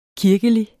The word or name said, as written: kirkelig